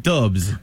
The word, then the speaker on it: DVBBS: Là, j'ai vu DVBBS, mais ça se prononce comment? – Dubs.